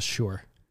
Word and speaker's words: Shure: So my microphone right here is a Shure. – Yeah, it is. – Sucka. – Oh, right. For sure. – Oh, for sure. I think that's how it's pronounced in Minnesota. It's a Shure microphone.